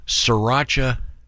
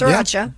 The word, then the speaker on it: Sriracha: Once again, including in the realm of food, there is a sriracha shortage. All right. Am I pronouncing it correctly? – Sriracha, yeah. – Sriracha, because it's spelled sriracha. – Like Sri Lanka? – Yeah.